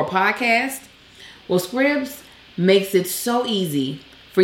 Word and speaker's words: Scribd: They give us the pronunciation. Scribd, like ribbed or fibbed.